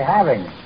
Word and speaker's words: having: Don't say having some fun. Say having. Pronounce the G.